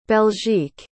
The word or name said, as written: belgique